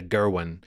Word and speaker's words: Gawain: And it, you know, the journey he goes on that, I always pronounce it Gawain. In the movie, they say something like closer to Gerwin or Gawain.